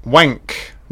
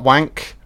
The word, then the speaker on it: Wenk: So Vamp, directed by Richard, I'll pronounce this right, Wenk, not Wank, Richard Wenk.